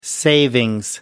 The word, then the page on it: savings: British English